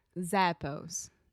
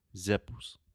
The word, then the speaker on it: Zappos: So, a normal Brazilian without pronunciation training would probably say zippos, zippos, something like that. But it's zappos.